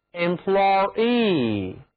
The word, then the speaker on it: employee: 首先动词 employ 字母 oy 读作 oy，雇员 employee，因为我们要特别去强调 double e 这个后缀的分量，所以啊就要把一个长元音 e 加在 employ 后面，读作 employee，重音是非常明显的。